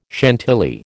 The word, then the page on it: Chantilly: American English